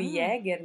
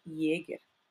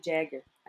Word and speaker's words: Jäger: Na verdade, se a gente for pronunciar meu sobrenome certinho, é Jäger, que é alemão, né? Jäger. Mas como eu sou teacher de inglês, meus alunos sempre me chamavam de Jagger, Aline Jagger.